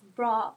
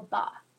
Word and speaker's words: brer: While modern Americans generally pronounce the second R in brer, the original pronunciation was bra or ba.